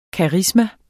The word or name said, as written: karisma